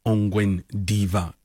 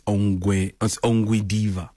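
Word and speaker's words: Ongwediva: Also, another listener came through to tell me, Mooks, it's not Ongwen Diva. It's Ongwe Diva.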